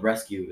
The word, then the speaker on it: RSQ: It's basically Tilly's has their own brand called Rescue. It's spelled RSQ and they basically like have like shirts, pants, jeans, like all that kind of stuff.